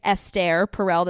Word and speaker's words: Esther Perel: It's Esther Perel. That's how you pronounce it.